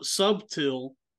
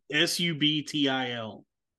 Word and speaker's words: subtil: The way that word is spelled in here is S U B T I L, subtil. Subtil. Mine says craft. – I think the I think it's supposed to be subtle. Now the serpent was more subtle.